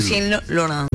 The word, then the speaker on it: Lucien Laurent: El señor Lucien Laurent. – ¡Mierda! Good pronunciation. – Le digo a los oyentes porque Sandy fue la que me dijo cómo tenía que decirlo. – Lucien Laurent.